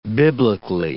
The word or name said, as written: biblically